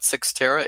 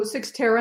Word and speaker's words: Cyxtera: So before we get too far along, why don't you tell people what Cyxtera is and what you sell and who you sell to? – Absolutely. So Cyxtera, and thank you so much for pronouncing it properly.